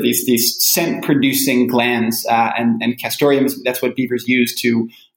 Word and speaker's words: castoreum: Castoreum. That's what I say. And other people pronounce it differently. I hear people say castorum without the E. That's how a lot of trappers say it.